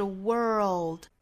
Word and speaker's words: world: 那首先我们要注意的就是 world 这个词，美式的发音是 world， world。我们之前早读也经常讲这个词啊，英音的话是 world，美音是 world，有一个卷舌音在里头的。